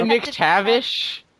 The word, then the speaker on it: McTavish: McTarvish. Wow. – I don't know how to pronounce it. That's how I've been pronounced. – Isn't it McTavish? – McTavish? – There's no R in there.